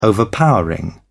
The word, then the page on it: overpowering: British English